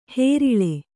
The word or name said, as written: ಹೇರೀಳೆ